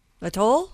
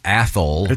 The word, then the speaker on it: Athol: Now, this person is writing from A-T-H-O-L, Idaho. – Atoll? – No, Athol. – I believe it's Athol.